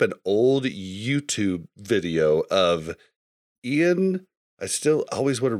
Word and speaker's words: Ion: Yeah, it's Ion. Like, how you would spell the word Ion. – But it is pronounced Ian.